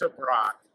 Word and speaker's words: Tebrake: Is it Tebrake? How do you pronounce the last name? – I mean, it gets butchered all the time, so but it's Tebrake.